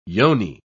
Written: yoni

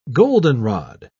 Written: goldenrod

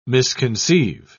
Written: misconceive

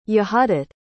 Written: yehudit

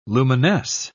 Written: luminesce

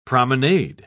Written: promenade

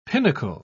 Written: pinnacle

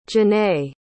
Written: Jenae

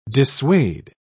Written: dissuade

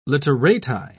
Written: literati

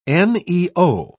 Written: neo

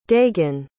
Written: dagon

